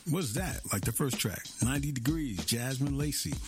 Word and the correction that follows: Yasmin Lacey: I'm probably saying that wrong. I think it's Yasmin Lacey from East London.